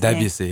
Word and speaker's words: Debussy: I don't know if that's how you say his Debussy. – Name. Debussy. I knew I said it wrong.